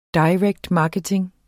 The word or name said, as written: marketing